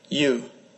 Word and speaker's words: ewes: Here's how you say it. Come on. – You. You. You. – Did he need to do it 3 times? How fucking dumb am I? – You. – How do you say it? – You. – Yai? – You. – Oh, you. All right, you.